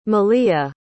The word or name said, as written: maliyah